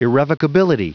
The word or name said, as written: irrevocability